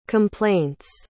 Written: complaints

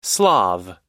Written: slav

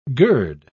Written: gird

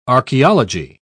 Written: archaeology

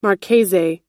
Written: marchese

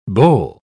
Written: bole

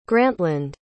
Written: grantland

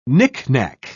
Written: knickknack

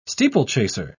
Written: steeplechaser

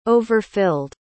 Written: overfilled

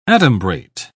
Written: adumbrate

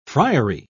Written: friary

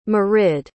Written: marid

